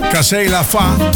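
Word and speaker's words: Casser la voix: En daarvoor hoorde je Patrick Bruel. Cassé La Fa. Zo moet je het uitspreken.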